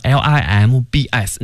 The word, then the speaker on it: limb: 四肢那个词呢，limbs 那个 b 不发音，就是 limb。